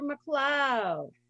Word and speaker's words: McLeod: Hello, Dr. McLeod. – Hi. – Thanks. Am I saying that right? – Yes, McLeod? You are. Despite the spelling, it is McLeod.